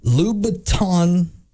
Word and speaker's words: Louboutin: One of the items left in the Uber was a single red bottom Louboutin heel. – You pronounced that correctly. – Did I? – Yes, yeah.